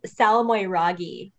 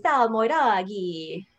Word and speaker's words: Salmoiraghi: Well, I don't even say it right. I think the Italians say Salmoiraghi.